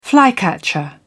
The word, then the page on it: flycatcher: British English